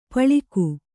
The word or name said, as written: ಪಳುಕು